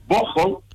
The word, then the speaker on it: Bocholt: Se escribe en español y Bocholt. – Ah, Bocholt. – La pronunciación alemana, ¿no?